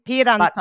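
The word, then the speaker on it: Apeiranthos: And then, we did go on to Apyranthos. Mm-hmm. I'm sure I'm not pronouncing that properly. – Apyranthos.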